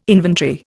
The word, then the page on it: inventory: Indian